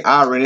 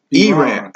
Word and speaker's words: Iran: I'm pronouncing it wrong. It they don't say Iran. They say Iran.